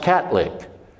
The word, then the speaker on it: Catholic: Well, in South Louisiana, the way the TH is pronounced, the word Catholic is pronounced Catlick.